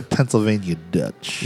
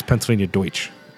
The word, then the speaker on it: Pennsylvania Deutsch: So Pennsylvania Dutch. – You know that's just a mispronunciation, right? – I don't give a shit. – They're German. It's Pennsylvania Deutsch.